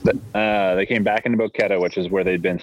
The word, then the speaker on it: Boquete: I just lost the name of the town we've been hanging out in, but Boticate. I'm saying it wrong. – Right, anyway, now. No, yeah, it looks like it's a Boquete.